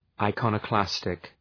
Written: iconoclastic

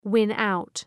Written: win out

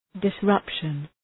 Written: disruption

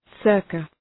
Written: circa

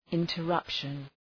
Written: interruption

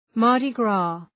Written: Mardi Gras

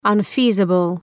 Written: unfeasible

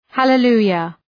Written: hallelujah

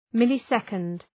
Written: millisecond

